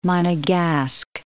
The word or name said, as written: Monegasque